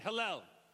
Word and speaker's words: Hallel: Say it again halal. There you go. You got to say it like a Hebrew, like halal.